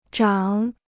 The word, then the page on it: 涨: zhàng